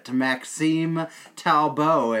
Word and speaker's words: Maxime Talbot: Maxime Talbot, if you want to say it in the French pronunciation, and Jay Bomeister kicked his ass.